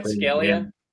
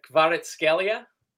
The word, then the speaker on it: Kvaratskhelia: Kvaratskhelia. – Who's that? – Kvaratskhelia. A little Georgian kid that plays for Napoli, you know. – That's how you pronounce his name, isn't it? – Yes.